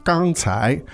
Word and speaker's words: 刚才: First tone and second tone, 刚才, meaning just a second ago, right?